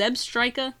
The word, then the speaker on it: Zebstrika: Is it Zebstrika? – Yeah. – That's You're saying it right.